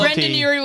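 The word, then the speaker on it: Brendon Urie: Brendan Urie will come to us himself.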